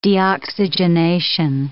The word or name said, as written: deoxygenation